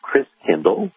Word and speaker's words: Christkindl: And the German pronunciation of Christ child is Christkindl, like kindergarten, kindercare. Kindl means child.